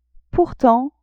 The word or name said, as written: pourtant